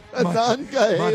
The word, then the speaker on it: Monongahela: Monongahela.